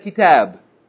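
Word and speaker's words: kitab (كتاب): Like you don't say kitabun. You say, give me that kitab. Kitab.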